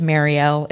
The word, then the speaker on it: Marielle: I pronounce it Marielle. It's spelled M-A-R-I-E-L. My family pronounces it differently.